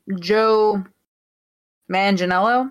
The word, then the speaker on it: Joe Manganiello: Joe Manganiello. Is that how you say his name? – Yeah, Deathstroke.